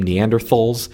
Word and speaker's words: Neanderthal: Now, first off, I will say, I call them Neanderthals. If you talk to any scientists, typically they call them Neanderthals. That is, pronouncing the TH as a T because it's based on the German pronunciation.